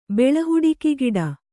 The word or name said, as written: ಬೆಳಹುಡಿಕಿಗಿಡ